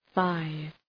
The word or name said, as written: five